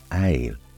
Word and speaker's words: ail: Garlic in French is pronounced ail and spelled A-I-L. The word garlic derives from Old English, garlic, G-A-R-L-E-A-C, meaning gar, speak, and leek, as in a spear-shaped leek.